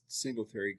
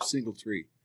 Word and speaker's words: Singletary: He couldn't he couldn't pronounce Singletary. He called him Single Tree.